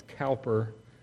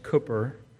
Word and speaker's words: Cowper: But I wanted you to have this open to you so when we get there, I think this hymn of William Cooper proves a point. And I understand that his name looks like it's Cowper, but it's pronounced Cooper.